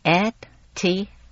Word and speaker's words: etiquette: ETIQUETTE。 – Oh, etiquette，原来是法文，意思是礼节。 – 哦，原来是让我去参加礼节训练班啊。哎，这个词儿是怎么发音的？ – Etiquette，那个 QU 啊是发 K 的音，而不是发 Q 的音。